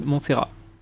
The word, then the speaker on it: Montserrat: on écoute le morceau Flore de Montserrat. Je suis bon en prononciation ? – Tout à fait.